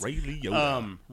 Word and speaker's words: Ray Liotta: I said his name right. – Guys, I've been saying like Ray Liotta or something for the last like 45 minutes. – Ray Liotta. – Ray Liotta.